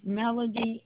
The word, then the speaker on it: Melody: My first name is Melody, M-E-L-O-D-Y, and my last name is Cole Gamble.